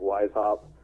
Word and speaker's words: Weishaupt: I've got an author page for Isaac Weishaupt.